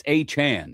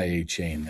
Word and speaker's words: Achane: It's Achane. It's not Achane.